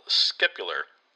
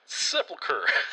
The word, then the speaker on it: sepulchre: Those stones are said to be from the original scapular. I can't pronounce the word. – Sepulchre.